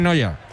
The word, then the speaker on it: Neuer: Yo digo, ese se pronuncia, es que es Neuer, pero se pronuncia casi igual, sí, se pronuncia en Noya.